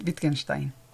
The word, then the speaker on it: Wittgenstein: Ludwig Wittgenstein.